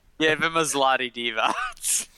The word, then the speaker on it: zloty: Z-L-O-T-Y. – Yeah, it's like golden. – How do I pronounce that? Zloty? – Zloty.